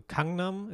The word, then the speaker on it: Gangnam: So Kangnam ist ja eigentlich Gangnam, sagen wir ja immer.